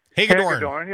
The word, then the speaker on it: Hagedorn: and then Hilgendorf, or whatever his name is, Hilgeborn, or Hagedorn. – I might get the name wrong. – Hagedorn.